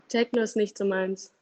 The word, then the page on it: Techno: German